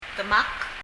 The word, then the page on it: demak: ðəmʌk